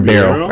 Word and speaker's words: Ribeiro: Ribeiro.